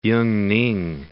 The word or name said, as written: Yungning